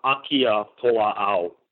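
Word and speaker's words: Acciopolaau (ʻAkiapōlāʻau): So if you notice it, it has at least two okinas in the word. – Yeah, at least two okinas. Yeah, one right at the beginning and the second one in the string of vowels at the end there. – Yeah, so it's just pronounced Acciopolaau. – Acciopolaau. – So there's a little pause between the two A's.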